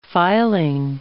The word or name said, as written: filing